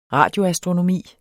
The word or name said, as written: radioastronomi